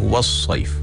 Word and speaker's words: wasaif: Kalau huruf waw itu adalah huruf yang nipis, bermakna kita kena membaca dengan nipis, dan sod itu bacanya tebal. Kalau tidak dijaga, maka kita akan menjadikan waw tu tebal sebab kita nak standby kepada huruf yang setelahnya. Sebagai contoh, kalau kita bacanya wa, maknanya wa tu adalah nipis, wa baru masuk so, kan? Maknanya wasaif. Tapi kalau kita tak terjaga dengan kalimah yang nipis ini, huruf yang nipis, maka dia akan jadi wa.